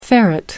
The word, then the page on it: ferret: American English